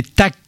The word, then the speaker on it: TACK: Ce duo, c'est TAC, ça s'écrit T.A.C.K, comme un acronyme, mais qui n'a aucune signification.